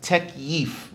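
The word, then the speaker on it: takyif: It's pronounced tekyif. Repeat it. Tekyif. Tekyif.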